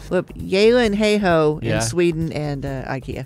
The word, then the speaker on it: Gyllenhaal: Is the actual Swedish pronunciation of Jake and Maggie Gyllenhaal. – Yeah. – Okay. It's their name, which is Yilenheho.